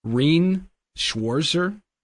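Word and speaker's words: Rean Schwarzer: I'm going to butcher this name, Rene Schwarzer. Does anybody know? Anybody help me out? – No. – I think you did it correctly.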